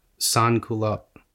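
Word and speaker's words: sans-culottes: Sans culottes? – Sans culottes. – Okay, I was relatively close, means, but I pronounced the S at the end of culotte. – Yeah, you don't pronounce the S's.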